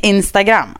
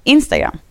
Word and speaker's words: Instagram: Då säger man så här, Telegram, postogram, Instagram. Alltså då lägger man betoningen på gram. Är man den yngre generationen säger man Instagram. Och så ligger betoningen där.